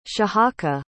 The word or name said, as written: shahaka